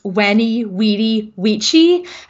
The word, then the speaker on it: Veni, Vidi, Vici: So now technically in ancient Rome, those words would have been pronounced Veni, Vidi, Vici, as the V was pronounced the way we say W, but that sounds ridiculous and I refuse to do it.